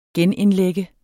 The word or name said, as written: genindlægge